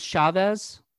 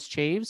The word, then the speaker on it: Chaves: Well, this movie was directed by Michael Chaves, who I think that's how you say, I thought it was Chavez, you know, yeah. – But somebody actually pronounced it as Chaves.